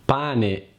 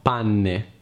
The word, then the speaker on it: pane: Pane e non panne. Pane. Panne.